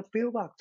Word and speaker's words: Baerbock: 这个呃，Baerbock，我有朋友纠正我的发音，是 Baerbock，他要来担任。那 Baerbock 他的本身的立场是非常的强势的，他对俄罗斯、对中国、对这些相对来说人权不是很好的国家，Baerbock 他的立场是非常坚定的。